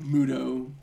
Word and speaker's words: Muto: Is it Muto? Is that how it's pronounced? Muda? Muto? – Muto.